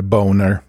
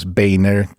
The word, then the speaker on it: Boehner: så gillar jag att uttala det boner. Men det ska uttalas Boehner.